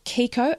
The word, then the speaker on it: Kiiko: Okay, so Kiko, and that's spelled K-I-I-K-O.